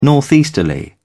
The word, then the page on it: northeasterly: British English